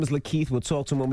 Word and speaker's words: LaKeith: Goes by the name of Lakeith.